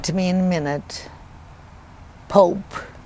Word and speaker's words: Pope: It could be, it would be without the E on the end, it would be pop. – Yeah. – But with the E on the end, it in English would be Pope. And they pronounced it in the Romanian language as Pope, but it was spelled P-O-P.